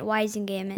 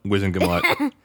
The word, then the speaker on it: Wizengamot: The Wizengamot. That's how it's pronounced. – Mommy was saying it Wizengamot. – Nope, Wizengamot. They say it in the movies, so I'm going to go with their pronunciation.